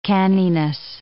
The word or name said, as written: canniness